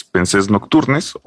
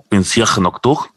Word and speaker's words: Pensées Nocturnes: Es Pensés Nocturnes o Pensamientos Nocturnos, que se debe pronunciar como Pensier Nocturne o algo así.